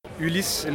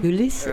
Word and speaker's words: Ulysse: Ulysée la Cryptide. So Ulysse is my name. – Ulysse le Cryptide. Ulysse ist mein Name, so wie Odysseus, aber halt auf Französisch.